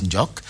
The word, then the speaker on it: Ndjok: Maintenant, on sait, hein, le mot Ndjok se prononce pratiquement partout de la même façon. Les Bassa disent Ndjok. Et là-bas, on dit Eyumo Ndjok.